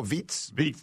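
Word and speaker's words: Vít: Vic Krejci. – Oh, no, Veet's healthy. It's Krejci.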